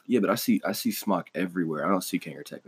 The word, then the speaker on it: SMOK: People start out with like the normal brands, Smok or Smoke, depending upon how you say it. It's SMOK.